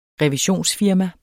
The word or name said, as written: revisionsfirma